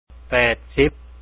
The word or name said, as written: 80